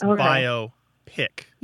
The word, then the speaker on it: biopic: So it divides, the syllables divide between the O and the P. So it's biopic.